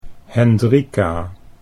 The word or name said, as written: Hendrika